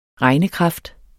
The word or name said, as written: regnekraft